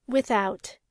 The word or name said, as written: without